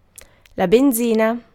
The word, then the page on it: benzina: /bɛn.ˈʦi.na/